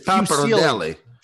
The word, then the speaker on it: pappardelle: Did I pronounce that wrong, by the way? – Yeah, you butchered it. – I'm going to have to send this segment out to the Audi Club group chat because I would love to get a video review of this review from Lou because his pronunciation, like the way Joe pronounced it. It's pappardelle.